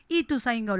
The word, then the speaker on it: Ituzaingó: Ya te lo digo, Sufre a Tegui 685 a pasitos de la estación Ituzaingó.